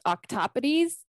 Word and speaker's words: octopodes: and now it's my favorite part because it's Greek, it's pronounced octopodes.